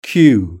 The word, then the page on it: cue: British English